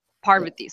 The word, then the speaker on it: Parvati: I'm saying that name wrong. – Yes. Oh, for everyone listening, you can see my pinned tweet, but it's Parvati. So the first syllable is the strong one.